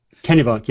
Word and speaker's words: Kennebunk: Kennebunk, yeah.